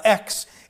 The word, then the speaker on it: Aix: And in 1545, they got a new president of parliament in the Parliament of X. It's pronounced A-I-X. And I had to look it up, the pronunciations, and some French dude said X.